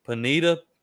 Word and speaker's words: Pineda: So, Jack, you know, coming out of Baylor this past season, real quick though, for an ignorant redneck, is it Pineda? Okay. Jack Pineda. Okay. Thank you. Oh, I mean, we were talking about that earlier, and I was like, I don't want to say it like some idiot and then look dumb. So I was like, I'll just ask him. – But no, Pineda, you're good.